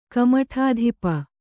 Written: ಕಮಠಾಧಿಪ

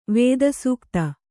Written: ವೇದಸೂಕ್ತ

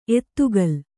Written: ಎತ್ತುಗಲ್

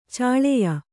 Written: ಛಳೆಯ

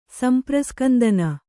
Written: ಸಂಪ್ರಸ್ಕಂದನ

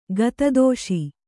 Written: ಗತದೋಷಿ

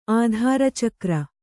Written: ಆಧಾರಚಕ್ರ

